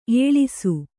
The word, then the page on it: ಎಳಸು: eḷasu